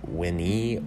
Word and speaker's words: veni: The plural uses the ending te, and that's going to render the winni or winnite. So that's going to be spelled as such, V-E-N-I. Remember, V's are pronounced as W's in Latin. And in the plural, it's going to be V-E-N-I-T-E, meaning come, with an exclamation mark, either referring or directly addressing a singular person or a plural amount of people.